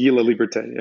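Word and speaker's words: Laliberté: Guy Laliberte, is that how I pronounce it? – It's Guy Laliberte, yeah.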